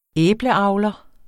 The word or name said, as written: æbleavler